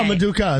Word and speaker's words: Maduka: Maduki? I finally said it right. Maduka Okoye. – Oh, Maduka is right.